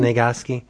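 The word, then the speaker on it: Sniegoski: It is a product of Mr. Mignola, Christopher Golden, and Tom Snegoski. Yes? Is that how you say it? Mm-hmm. Snegoski.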